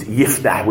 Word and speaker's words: Yiftah: Actually, in the original, it's neither of those. It's Yiftah, which means to open.